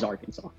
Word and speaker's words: Arkansas: And does anyone actually pronounce it Arkansas, or is it always Arkansas? – It's always Arkansas.